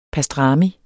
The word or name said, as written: pastrami